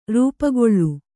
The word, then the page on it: ರೂಪುಗೊಳ್ಳು: rūpugołu